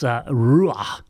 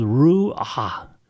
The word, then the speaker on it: ruach: The Hebrew word for breath is, it's a real guttural word. It's ruach. It's like two syllables, ruach, and you got to breathe it out.